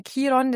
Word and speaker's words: Chiron: Chiron, hast du gesagt, ich sage immer Chiron, das ist ja so diese unterschiedliche Aussprache, aber das ist so diese Wunde, dieser verwundete Heiler in unserem Horoskop.